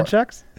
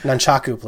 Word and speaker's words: Nunchaku: Nunchucks? – No nunchucks. – Okay. – Nunchaku, please.